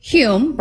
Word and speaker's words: Hulme: And then SJ Hulme, right? Said it right? – Yes, SJ, yeah, you got it.